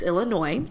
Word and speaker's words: Illinois: It's not pronounced Illinois? – No. It's not, it's a very loud state. – It is a loud state, but it's not Illinois. Um, it's Illinois.